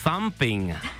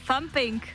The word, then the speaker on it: thumping: Thumping. – Thumping. – Jo, to th je toho, vyslovuje se to tak, že strčíš jazyk mezi zuby.